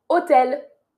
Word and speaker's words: hôtel: Attention, on ne prononce pas le H dans hôtel en français.